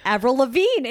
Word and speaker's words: Avril Lavigne: Avril Lavigne. It rhymes with Adele Dazeem.